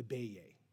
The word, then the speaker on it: Beje: The family lived together in this quirky, multi-level home that was affectionately called the Beje. So spelled B-E-J-E, pronounced Beje.